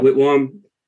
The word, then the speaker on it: Whitwam: Charles Whitwam, is that how I pronounce it? – Whitwam. – Yep. Whitwam.